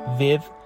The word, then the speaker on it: with: تلفظ with رو دقت کنید، W-I-T-H میشه with. I'm with you. اینطوری نیست که بگیم with. حالا خیلی ها with تلفظش میکنن یا with تلفظش میکنن که هر دو اشتباهه و باید with تلفظ بشه، یعنی زبانتون باید با دندونهای بالا تماس برقرار کنه و خونده بشه with.